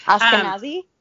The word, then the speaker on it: Ashkenazi: And really denied his Puerto Rican identity, actually would tell everybody that he was an Ashkenazi Jew. I think I said that right. – Ashkenazi? – Yes, thank you.